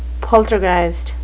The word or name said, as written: poltergeist